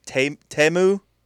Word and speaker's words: Temu: I went to see my mom the other day, and she had a new order from, which I now know it's pronounced Temu, all the commercials on the Super Bowl.